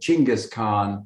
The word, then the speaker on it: Chinggis: They pronounce it Chinggis Khan.